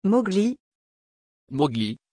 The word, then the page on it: Mowgli: French